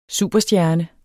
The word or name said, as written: superstjerne